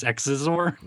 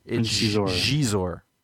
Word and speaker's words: Xizor: What was I supposed to say? Prince Xizor? – No, but there's... Yeah, I mean, people... – Zizor. – Shizor.